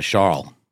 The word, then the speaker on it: Charles: Not a hard S on the end, just a Charles.